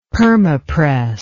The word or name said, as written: permapress